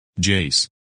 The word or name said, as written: Jace